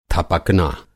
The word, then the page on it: थपकना: Hindi